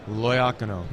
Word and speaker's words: Loiacono: Now, give me the pronunciation. Loacano? – Loacano. – Loacano. – Good Italian name.